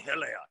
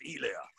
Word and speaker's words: Hilaire: ain't Hilaire, it's Elaire. Al Michael, get Al Michael on the phone. Somebody tell him ain't no ancient Elaire. Coach, it's spelled with an H. I know, but we don't say no H down here, except when we say here.